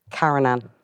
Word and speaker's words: Karen Ann: Right, Caroline, I'm Fiona. – Karen Ann. – Oh. – You've already said it wrong. – Yeah. Have I, yeah? It's your luck, we'll roll right. Cut that. – What is it? Caroline. – Karen Ann.